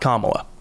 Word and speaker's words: Camala: So this planet is named Kamala. – Kamala. – Not Kamala. – Kamala. – That is the vice president. – Ah. Okay. – This one is Kamala, and it is spelled C-A-M-A-L-A.